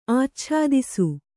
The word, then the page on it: ಆಚ್ಛಾದಿಸು: Kannada